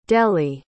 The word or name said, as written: dellie